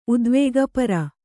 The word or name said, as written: ಉದ್ವೇಗಪರ